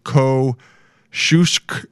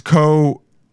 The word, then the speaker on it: Kosciuszko: Go, I, uh, I, asked this guy today, this Polish guy today, how to pronounce Kosciuszko. Uh, it's a street in New York, and it's Kosciuszko. It's Kosciuszko.